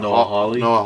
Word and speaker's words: Noah Hawley: That's directing Noah Hawley.